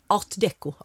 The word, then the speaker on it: art deco: Det var en saga, ett stort fantastiskt ADK-hus med en ringmur runt, för det har nämligen varit ADK, förlåt. – Art deco.